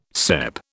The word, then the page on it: cep: British English